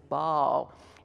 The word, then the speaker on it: Baal: Well, the first time I heard that some years ago, I realized that pronouncing B-A-A-L as Baal was really Southern. And so in that, you'll hear the choir sing it as Baal. And if you did research it, what you're going to see is it's a two-syllable word, Baal.